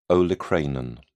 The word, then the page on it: olecranon: British English